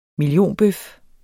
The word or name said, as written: millionbøf